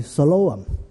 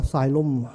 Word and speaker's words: Siloam: 二十几年前我最小的仔出世的时候呢，我刚刚读到这个约翰福音第九章，所以我改了我细个仔名叫做西罗亚，用了这个词改了做个名，读音是 Siloam，千祈不要读了 Siloam， Siloam 就惨了，三个音 Siloam，奉差遣。在这里我们没什么见到，在美国，在甚至我去到印尼都见到有教会的名，有福音机构的名叫做 Siloam。